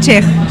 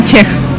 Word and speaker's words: Chekh: es, es Chej. Otra, otro que no sabe. Ahora. Es Elena Chej.